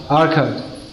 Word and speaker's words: Arcot: I was in Arcot two nights ago. How do you pronounce it? – Arcot.